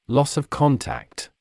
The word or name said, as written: loss of contact